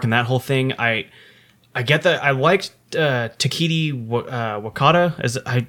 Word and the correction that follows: Taika Waititi: I apologize if I'm pronouncing the name wrong. – Hawaiian Island. – He was the director. – Taika Waititi. – Is that what it is? Okay. – Yes.